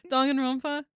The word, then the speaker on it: Danganronpa: Danganronpa? – Yeah.